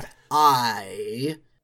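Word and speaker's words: Deth I: Uh, so I always said Dethel, Dethel, Dethel and then at some point I realized it's supposed to be an uppercase I, so it's yeah. – Death I.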